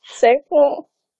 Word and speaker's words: Sabroe: Sabro. – Sabro? – Sabro. – Nej, uden E. Du skal ikke udtale E'et.